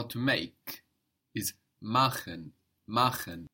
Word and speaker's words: machen: That's spelled M-A-C-H-E-N. And here we have friction on the C-H, machen. – Machen. – So it's only soft when we have an I before, like ich, mich, nicht.